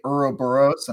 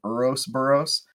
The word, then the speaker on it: Ouroboros: I was supposed to say Ouroboros, and I accidentally said Ourosboros.